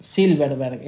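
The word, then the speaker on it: Zylberberg: Silverberg, está bien, entonces lo voy a pronunciar bien.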